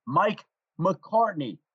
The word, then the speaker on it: McCartney: Notice how I pronounced that name, Mike McCartney.